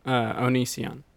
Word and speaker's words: Onision: Oh, Onision.